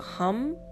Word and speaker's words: Ham: So you would see it as ham, but again, when you pronounce it, it's like a hum. So like hum.